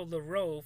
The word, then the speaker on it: Leroux: September 12th, Alejandro Leroux forms a new government in Spain, and I think I pronounced that name correctly, because I know the J is silent, and I know, well, Leroux sounds French, so, yeah.